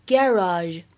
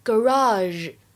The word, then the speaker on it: garage: 而么我这个单词呢，需要强调一下，它的话呢，单词的发音比较多，在英式英语当中呢，一般来说读作 garage garage，美式当中呢会读成 garage garage，或者呢像我们今天美剧当中读成 garage 也是可以的。